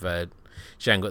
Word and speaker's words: Django: He has the conversation with Django at the bar, and he's sort of like, oh, it's Django, the D is silent, and he's like, I know.